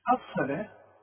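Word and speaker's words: الصلاة: ويقيمون الصلاه، الصلاه مطلوبه بالواو لكن تنطق بالالف، شوف الصا، الصلاه، واخد بالك.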